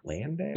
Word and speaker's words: Landale: Okay, so have you heard of, I don't even know how you say his name. He's on Phoenix, Jock Landale? – Yeah. – Landale? – Yep.